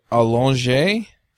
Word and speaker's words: Allongé: Is it Allongé? – That's my understanding of the pronunciation, yeah.